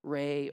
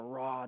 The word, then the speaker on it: Ra: For, as has already been mentioned, Pharaoh is understood to be the very incarnation of the sun god, Re or Ra, depending on the pronunciation.